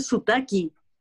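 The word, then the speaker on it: sotaque: No, no, no, a, propósito de acento, eh, hay una cosa que me parece muy loca, paréntesis, que eh, ¿sabes cómo se dice acento en, de acento, de tener un acento en portugués? Mm. Te dicen sutaki.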